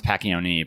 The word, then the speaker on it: Pacchione: So what most people do is because it's P-A-C-C-H. Most people are like patch, like patching. – I was saying patch for a while. – Yeah. Well, and it's supposed to be, it's actually pronounced Pacchione, but we're American and somewhere along the lines, the Italian pronunciation got dropped.